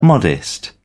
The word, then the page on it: modest: British English